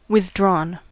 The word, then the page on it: withdrawn: American English